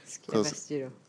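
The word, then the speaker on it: Sukiyabashi Jiro: It's called Tsukiyabashi Jiro. Did I say that right? – Yes, Tsukiyabashi Jiro.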